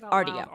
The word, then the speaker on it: Rdio: Rdio.